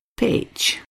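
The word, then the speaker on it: Pécs: So it's spelt P-E-C-S, and I decided I would email Anna and Danny and say, how do I say that? And they kindly responded with a little mini sound file that explained the mystery. So, we are off to Peach. How did I do?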